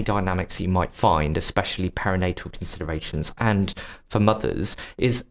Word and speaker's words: perinatal: But what are some common misunderstanding signs of parental mental health struggles? Did I say that right? It is, isn't it? – Perinatal. Thank you. Perinatal.